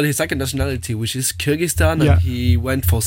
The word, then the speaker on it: Kyrgyzstan: Kyrgyzstan? – Yeah.